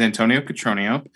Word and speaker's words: Antonio Cotronio: So as you said, my name is Antonio Cotronio. It rhymes.